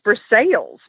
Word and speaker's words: Versailles: It's pronounced Versailles. She thought I was punking her. But no, it's Versailles.